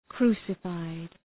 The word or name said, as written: crucified